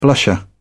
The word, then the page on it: blusher: British English